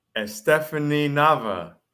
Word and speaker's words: Estefani Nava: So without any further ado, tonight's guest, Estefani Nava.